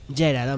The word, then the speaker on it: Geralt: Lo pronuncié bien esta vez. – Geralt, hombre, Geralt.